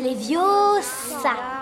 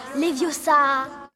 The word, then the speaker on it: Leviosa: Et puis d'ailleurs, tu ne sais même pas le prononcer. C'est Leviosa, et pas Leviosa.